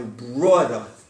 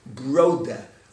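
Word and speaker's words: Braude: He knew it's Rabbi Broda. Am I pronouncing it right, Mark? Let me know. Broda. It's B-R-A-U-D-E, pronounced B-R-O-W-D-E-H.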